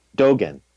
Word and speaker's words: Dogen: And Dogen, is that the way you say his name? – Dogen. – Dogen, thank you.